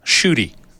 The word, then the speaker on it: Ncuti: Okay, so his first name is N-C-U-T-I, last name G-A-T-W-A. – Yes, and it's because of the Rhodesian background. – Yes. – The first name is pronounced Shooty. Oh.